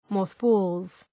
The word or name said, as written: mothballs